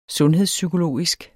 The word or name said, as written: sundhedspsykologisk